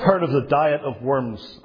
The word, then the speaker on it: Worms: As I said last time, it's nothing to do with eating worms. It's nothing to do with a diet that we talk about today. It was a convocation. It was a gathering at a place, properly pronounced Worms, in Germany.